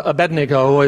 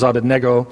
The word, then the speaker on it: Abednego: I know some of you said, how come you pronounce it that way? Well, Abednego is, I guess, the way we learned it as we were children, but the proper pronunciation, the Babylonian pronunciation, is Abednego, in honor of the Babylonian god.